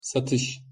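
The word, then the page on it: satis: Turkish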